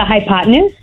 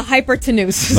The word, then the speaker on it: hypotenuse: The hypotenuse? – Yes. I was going to say the hypertenuse. Okay. So that's good. – Thank you. Right. The hypotenuse. Definitely.